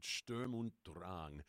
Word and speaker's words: Sturm und Drang: And by the way, having just released a new album called Sturm und Drang.